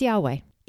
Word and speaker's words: Yahweh: Without going into too much detail, and you can study this more on your own, but when vowels were introduced, the most accepted way to pronounce the word was Yahweh. It has also been pronounced as Yehovah in Hebrew, and it later was converted to Jehovah.